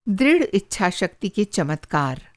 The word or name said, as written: इच्छा-शक्ति